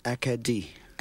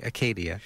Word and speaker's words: Acadie: Well, the English would be Acadia, and the French is Acadie. – Acadie, yeah. Acadie. So here I should say Acadia. – Well, it's actually written Acadie and should be pronounced Acadie.